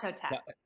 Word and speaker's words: Hatshepsut: Hatshepsut.